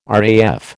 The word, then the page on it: RAF: American English